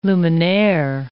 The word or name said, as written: luminaire